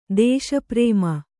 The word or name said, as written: ದೇಶಪ್ರೇಮ